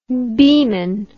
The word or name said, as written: beeman